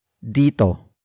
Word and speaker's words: dito: And finally, we have dito.